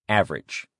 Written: average

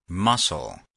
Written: muscle

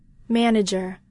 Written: manager

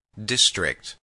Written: district

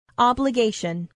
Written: obligation

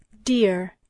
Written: dear

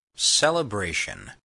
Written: celebration

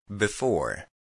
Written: before

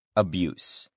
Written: abuse

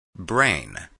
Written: brain

